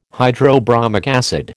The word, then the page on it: hydrobromic acid: American English